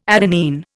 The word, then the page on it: adenine: Indian